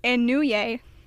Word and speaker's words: Inouye: and then I'm going to butcher this name. It's Daniel Inouye. – That was good. That was pretty good.